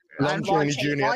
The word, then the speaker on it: Lon Chaney Jr.: Lon Chaney Jr. Thank you.